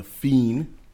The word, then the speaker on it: Pheen: It is called Land of the Fiend. – P-H-E-E-N.